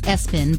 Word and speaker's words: ESPN: Someone's name was ESPN, called Espen, but it was spelled ESPN.